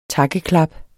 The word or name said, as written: takkeklap